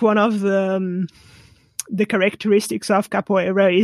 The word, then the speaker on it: capoeira: And I know I've capoeira.